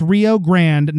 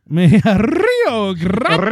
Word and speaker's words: Rio Grande: Maybe she was just mad because you didn't roll your R. – Rio Grande. – Rio Grande.